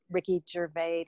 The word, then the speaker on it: Gervais: We just had was it the Golden Globe Awards where, was it Ricky Gervais, did I get his name right? – Yeah, yeah.